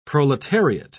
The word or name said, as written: proletariat